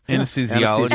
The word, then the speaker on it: anesthesiologist: And my grandmother was like a really, you know, like wanted to be a nurse and anesthesiologist. And did I say that right? Anesthesiologist? – Anesthesiologist. – Anesthesiologist. That's the one.